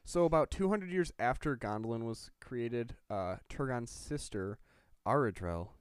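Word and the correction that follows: Aredhel: Is that how you say it? – Arathel. – You know, it's tough because there's no TH.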